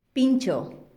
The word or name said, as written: pintxo